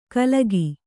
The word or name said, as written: ಕಲ್ಗಿ